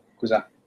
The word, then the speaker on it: cousin: Got to remember one thing that you really helped me with is you just don't usually or often don't pronounce the last letter in French. And that is a big, easy rule to help you. So cousin. Even though it ends in an N, cousin, say it again.